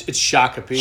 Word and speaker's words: Shakopee: It's Shakopee. Shakopee. I mean, Minneapolis Shakopee works. – Yeah. Nobody knows where Shakopee is. – Shakopee. – Is that how it's pronounced? – That's what Google will tell you.